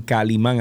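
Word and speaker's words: Kalimán: Ustedes van a Spotify, y ustedes ponen Calimán, así mismo como se escribe, K-A-L-I-M-A-N, con acento en la A.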